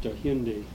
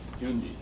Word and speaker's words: Hyundai: Huyandae? – Dai. – Dai, right. Uh-huh. Yeah. – And I guess it's pronounced Hyundai. – Hyundai, yeah.